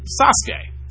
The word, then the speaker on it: Sasuke: And it is pronounced, at least the proper way in Japan, it is pronounced the great Sasuke.